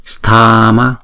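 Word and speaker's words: Mahāsthāma: Maha, Sthama, Sthama, Sthama, Sthama. Chữ Stha là hai nhịp, chữ Ma là một nhịp.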